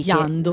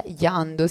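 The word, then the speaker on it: Jandó: Qui l'interprete invece è il pianista Jeno Genot, non so assolutamente come si legge e né di Giando. – Che nazionalità è. Giando, sì, Giando, c'è anche pure l'accento.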